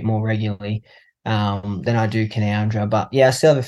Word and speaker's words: Canowindra: It's Canowindra and not Canowindra.